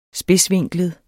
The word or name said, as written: spidsvinklet